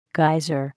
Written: geyser

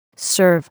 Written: serve

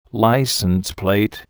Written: license plate

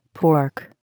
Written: pork

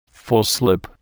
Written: full slip

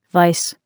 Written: vise

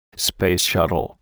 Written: space shuttle

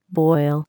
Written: boil

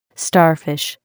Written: starfish

